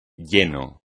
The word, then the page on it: lleno: Spanish